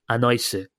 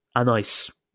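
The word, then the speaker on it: Anaïs: It's spelled A-N-A-I-S. Anne, right? – Come on. But she sent the katakana version. – Oh, damn. – There's an S in there at the end. – Okay, yeah. I've said it wrong. So Anne continues. It's okay, Chris said my name wrong. I've never met an English speaker who can pronounce it correctly. I'll help you this time using katakana. It is Anaise.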